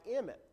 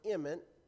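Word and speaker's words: emet: The Hebrew word, now get this, I don't know how else to pronounce it. I even looked it up trying to figure out if this was the right pronunciation or not, but it seemed like Emmet to me. It's E-M-E-T.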